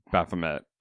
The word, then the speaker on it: Baphomet: The Satanic Church is that the Satanic Temple were the ones that commissioned that particular statue of Baphomet.